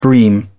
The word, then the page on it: bream: British English